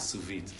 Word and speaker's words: sous vide: Is there any way to be matir a suvit? So I had no idea. By the way, suvit is spelled, and it's a French word. S-O-U-S-V-I-D-E.